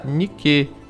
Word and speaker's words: Nike: Essa ideia de alada principalmente na deusa chamada Nike, né, que se pronuncia como aquela marca de tênis, né?